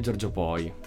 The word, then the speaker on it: Giorgio Poi: Invece con Elio e con Giorgio Poi, quindi il featuring piacerebbe con, l'ho detto giusto, no? Con Giorgio Poi,